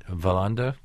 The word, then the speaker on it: Wallander: Wallander? – Yeah.